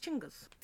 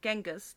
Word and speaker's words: Chinggis: His name is actually pronounced Chinggis. It's not Genghis, that's the anglicized version.